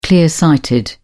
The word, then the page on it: clear-sighted: British English